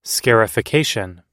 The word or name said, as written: scarification